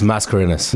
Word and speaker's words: Mascarenhas: And yeah, then, so I'm with Ryan and Russell Mascarenhas. Am I saying that right? – It's Mascarenhas.